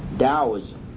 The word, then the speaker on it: Taoism: Now Taoism is spelled T-A-O-I-S-M. T-A-O-I-S-M. It's a Chinese religion and it's pronounced Taoism. Taoism. Now here's the history of Taoism. Lao Tzu, which is L-A-O hyphen T-Z-U, Lao Tzu was supposedly born between 604 and 570 B.C.